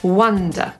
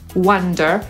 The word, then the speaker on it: wonder: Wzorcowa wymowa tego słowa, które jest również nazwiskiem znanego amerykańskiego muzyka, to wonder. Tak powie Brytyjczyk. Amerykanin powie wonder, czyli początek jest ten sam, na końcu po prostu nie zignoruje tej litery R.